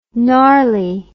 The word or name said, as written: narly